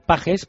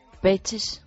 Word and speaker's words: Pages: yo digo pajes, pero siempre dice... – Peches. – Oyes, wow. Oyes. – La pronunciación de Oxford.